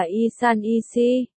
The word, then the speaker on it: 1314: 1314 đọc là Y San Y Si.